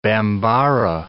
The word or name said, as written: Bambara